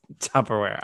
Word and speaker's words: Tupperware: It's Tupperware. I thought I said it right. – You said Tupperware. – It's because the R's in there, so it throws me off. I don't know. – You focus so much on the R that you get the P wrong?